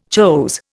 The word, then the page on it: chose: Indian